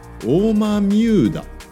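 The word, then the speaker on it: 大豆生田: 大きな豆の生えるあの生きるっていう生える田んぼと書いてオーマミューダオーマミューダって読む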